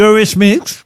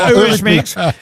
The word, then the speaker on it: Eurythmics: De Eurythmics. Je le dis bien. – Très bien. – Eurythmics.